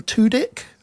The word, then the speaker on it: Tudyk: And it stars the comedic actor Alan Tudyk.